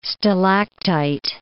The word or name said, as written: stalactite